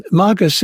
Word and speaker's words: magus: And magus is I use a Latin pronunciation of that word, magus, meaning like the magi, you know, the magus, the magician.